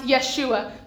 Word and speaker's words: Yeshua: This is the name that ties everything together, the name of Jesus, in Hebrew pronounced Yeshua, meaning a God who saves his people from their sins.